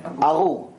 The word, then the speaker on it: Arur: How do you say it? – Arul. A-R-U-R. Arul.